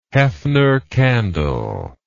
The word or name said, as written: hefner candle